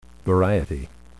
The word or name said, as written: variety